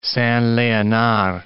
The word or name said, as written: Saint-Leonard